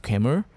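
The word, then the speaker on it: Kaemul (괴물): So the Korean translation of the movie, it's pronounced Kaemul, which means the monster.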